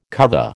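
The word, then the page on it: cuvée: American English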